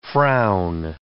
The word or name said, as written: frown